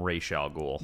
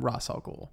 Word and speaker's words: Ra's al Ghul: show they call him Ra's al Ghul.